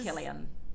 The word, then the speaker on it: Cillian: Killian Murphy, maybe that's how you say it. I can't remember. – Killian. Um, yeah, I, I think it's Killian.